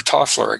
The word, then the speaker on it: Toffler: Alvin Toffler, or Toffler, I guess is how it's pronounced, made a lot of predictions.